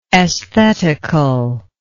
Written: esthetical

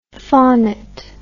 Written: faunet

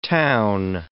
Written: town

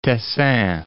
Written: Tessin

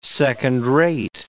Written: second-rate

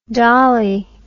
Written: dollie

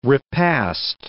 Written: repast

